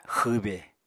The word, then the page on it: xwbe: Apatani